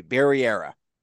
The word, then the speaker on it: Barrera: Barry, like the show on HBO, era, like a time in history, Barry era. – That's it. – Oh, man. Okay. Breaking news on Around the Nest, sharing exactly how to pronounce it.